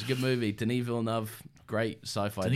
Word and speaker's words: Denis Villeneuve: Hi, I'm Denis Villeneuve.